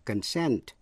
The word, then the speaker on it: consent: Hai từ nữa là concern, C-O-N-C-E-R-N, là quan tâm hay là liên hệ đến, và consent, C-O-N-S-E-N-T, nghĩa là thỏa thuận. Dù là danh từ hay động từ đều nhấn ở vần nhì và phát âm y hệt nhau.